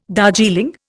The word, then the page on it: Darjeeling: Indian